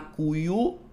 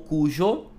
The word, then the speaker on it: Cuyo: Então a região central se, se chama Cuyo ou Cujo, né? As duas, vocês vão ouvir as duas pronúncias.